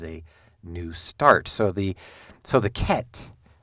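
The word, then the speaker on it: Ket (ח): So the Hebrew letter Ket, it's spelled, like if you read it in English, you might say Heth, H-E-T-H, or Hebrew might be C-H-E-T-H, but it is pronounced Ket.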